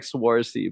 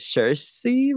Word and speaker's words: Saoirse: Yeah, the way you write it is like Swarzy, but the way you pronounce it is Saoirse Ronan, I think.